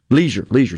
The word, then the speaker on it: Legear: What these guys have done, the key word that Mr. Ledger used, did I say it right, or is it Leisure? Say his name, the CEO. – Leisure.